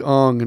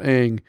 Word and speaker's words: Aang: The thing is like Shyamalan's like, that's the correct pronunciation. You would say Ong and Avatar, not Aang.